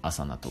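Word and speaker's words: Asana: まあ、あの、アルファベットで A、 S、 A、 N、 A で、アサナと読みます。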